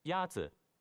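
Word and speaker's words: Yazi (鸭子): Yazi is pronounced with a first tone. In Mandarin, there are four tones, which are essential to distinguish the meaning of words. The first tone is a high-level tone.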